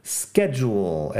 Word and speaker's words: schedule: Schedule es horario y empieza con S, C, H, pero la pronunciación sk, schedule, es una de las palabras un poco más complicadas de pronunciar en inglés.